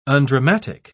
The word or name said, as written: undramatic